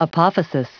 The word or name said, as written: apophysis